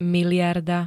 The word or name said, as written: mld